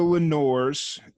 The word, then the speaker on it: Lenores: But so we're talking to the Lenores. Did I pronounce that band name correctly? – Yeah, that's it.